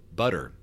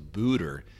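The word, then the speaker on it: Buder: The first time I saw her name, I said Madonna Butter, because B-U-D-D-E-R. I didn't know it was Booter.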